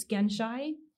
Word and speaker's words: Genshai: It's Genshai. It's spelled G-E-N-S-H-A-I.